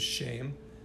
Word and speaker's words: Shem: So the name for Hebrew is Shem, not our English shame.